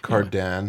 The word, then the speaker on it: Cardin: Cardin.